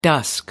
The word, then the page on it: dusk: American English